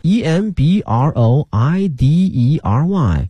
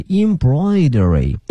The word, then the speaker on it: embroidery: 那么这个马尾啊，马大家都会 horse 尾巴 tail 啊 horse tail 马尾，后面这个刺绣哈，这个词啊有一定难度，但是呢这个词啊，希望同学们记下来，这是我们中国哈特别古老的、特别有历史的哈一门手艺或者是一个技艺哈。这个词看看它的拼写是 e m b r o i d e r y e m b r o i d e r y，它的发音是 embroidery embroidery，